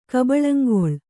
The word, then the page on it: ಕಬಳಂಗೊಳ್: Kannada